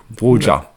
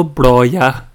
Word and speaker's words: Broja: Han er, han er bedre enn Armando Bro Brocha Nei, det var ikke Brocha du skulle uttale. Broja. Armando. Han, eh, han, har vært bedre enn han i det siste. Jon har jo lært noe, det skal være Armando Bloya.